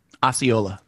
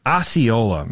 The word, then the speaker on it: Osceola: Well, how do you say it? Osceola? – Osceola. – Osceola.